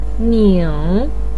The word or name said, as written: ning3